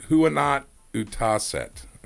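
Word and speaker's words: huonot uutiset: Huonot Utaset is how you say it.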